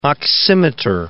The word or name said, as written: oximeter